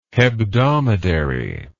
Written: hebdomadary

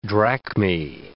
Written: drachmae

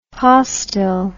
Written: postil

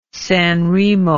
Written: San Remo

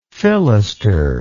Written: fillister

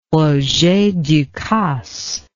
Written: roger ducasse

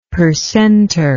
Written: percenter

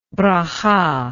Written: berakhah

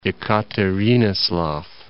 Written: Ekaterinoslav

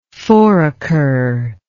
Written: foraker

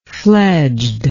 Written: fledged

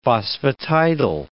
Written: phosphatidyl

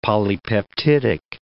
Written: polypeptidic

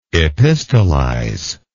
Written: epistolize

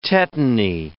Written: tetany

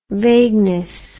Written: vagueness

